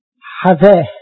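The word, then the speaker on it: حذاه (חוה): حواء بالعبري تتنطق حذاه، حذاه يعني حياة بالعبري، حذاه، حذاه اللي هي جت منها حواء.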